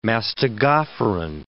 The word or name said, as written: mastigophoran